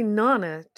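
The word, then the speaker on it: Neneh: Although I think it's probably, I don't think it is of your genre, the singer Nina Cherry. Is 57 today, And did she make that song Buffalo something? – Yeah, Buffalo Stance, yeah. She's actually Nana Cherry. Most people mispronounce her name.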